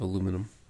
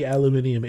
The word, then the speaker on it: aluminium: It's definitely promoting the benefits of aluminum for Dave from the UK. – Technically, aluminium is the approved pronunciation.